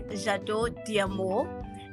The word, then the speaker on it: J'adore D'Amour: So the brand name is French. It's pronounced as J'adore D'Amour.